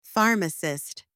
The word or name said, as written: pharmacist